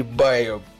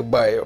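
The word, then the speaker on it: Bio: все-таки био или байо будет получше звучать, но все-таки в американском английском это абсолютно точно звучит как байо, поэтому, продолжай, продолжай.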